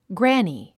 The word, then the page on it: granny: グラニィ